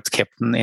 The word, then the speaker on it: Keptn: So first of all, I think it's a great chance to correctly pronounce the word Keptn in a German way, because people keep asking, how do you pronounce this Keptn and where does it come from? So it's the German phonetic of a captain of a ship, just in case people wonder.